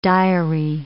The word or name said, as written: diary